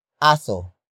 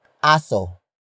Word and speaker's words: aso: It is the focus marker that introduces the main topic of this sentence, and that is aso. Aso. Aso. Aso is spelled as A-S-O.